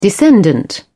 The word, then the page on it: descendant: British English